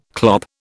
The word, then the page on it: clop: British English